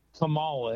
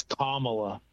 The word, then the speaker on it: Kamala: I went on there the other night, and they got pissy because I said Kamala, and they said it's Kamala.